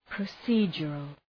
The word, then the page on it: procedural: prə'si:dʒərəl